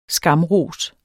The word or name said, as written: skamros